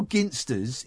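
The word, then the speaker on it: Ginsters: Yeah, it's a hard G. It's Ginster's.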